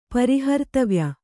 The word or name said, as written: ಪರಿಹರ್ತವ್ಯ